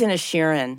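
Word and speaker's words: Inisherin: disclosure, um, I, I, believe it's Inishirin, Inishirin, and I think I've been mispronouncing yeah.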